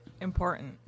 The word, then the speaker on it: important: How do you say important? – Important. – Yeah, that's important. Correct way to say it. How do you say C-L-A-Y-T-O-N, Steph? – Clayton.